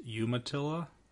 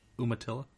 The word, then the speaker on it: Umatilla: I found out after our trip was over that it's pronounced Umatilla, not Umatilla.